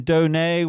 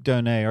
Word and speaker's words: Donat: In my attempt to be clever, I looked at your name, Donay, with that silent T, and the spelling D-O-N-A-T looks a lot like donut. Mm-hmm. So I have a donut question for you, Luke Donay.